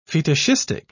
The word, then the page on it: fetishistic: British English